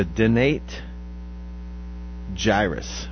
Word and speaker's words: dentate gyrus: And are you sure that's how you say it? – Dentate. Dentate. Dentate gyrus.